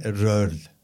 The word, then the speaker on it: Röhrl: Tezar, şey telaffuzunu yapayım mı? – Yap lütfen. Walter Röhrl. Walter Röhrl. Evet Röhrl.